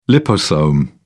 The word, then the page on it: liposome: British English